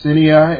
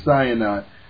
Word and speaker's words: Sinai: Sometime I'll call it Sinai, and sometime I'll say Sinai. I've heard it pronounced more than one way, so sometime I'll pronounce it different ways.